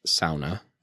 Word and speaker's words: sauna: Also, brief aside, it is pronounced sauna, as this book points out.